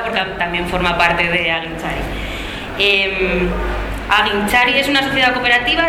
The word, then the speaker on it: Agintzari: Ellas son María y Josune, de Aguinchari, ¿lo digo bien? – Sí.